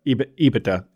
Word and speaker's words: EBITDA: Well, speaking of the DA in EBITDA, or EBITDA is my new pronunciation. Somebody called me out for my putting emphasis on the wrong syllable there.